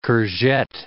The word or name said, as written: courgette